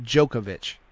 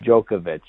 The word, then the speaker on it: Djokovic: I think it's Novak Djokovic. – The D is silent. Djokovic. Djokovic. – Djokovic. All right, there's a little ooh in the middle of it.